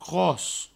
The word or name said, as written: cros